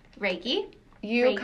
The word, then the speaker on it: Reiki: What is Reiki? Is that how you say it? – Reiki.